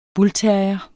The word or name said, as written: PITBULLTERRIERE